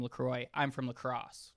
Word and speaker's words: La Crosse: So La Crosse, this is La Croix, because that's where the La Crosse and St. Croix rivers meet.